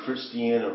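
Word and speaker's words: Christina: In Wilmington, there's the Christiana River, right? Is that how you say it? – Yeah.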